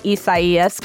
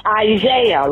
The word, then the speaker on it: Isaias: And even though many meteorologists were pronouncing Isaias correctly, they were still getting angry voicemails. – You all are pronouncing the name of that hurricane wrong. It's Isaiah. Look at the word. It's a Bible name. You all sound so stupid and silly when you're saying Isaiah.